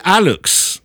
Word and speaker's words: Alux: His article says, in Maya culture, the Alux, I think it's Alux, A-L-U-X-E, but I think it's pronounced Alux. – That's how I'd say it, yeah.